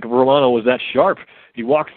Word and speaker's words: Romano: Um, and our friend from up north will love to hear that Jordan Romano out of Markham, Ontario, or uh, Romano, I guess, as it's pronounced.